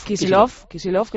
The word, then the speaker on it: Kicillof: el Axel Kicillof o Kicillof, no sé muy bien cómo lo pronuncio. – Kicillof, que lo pronunciáis así,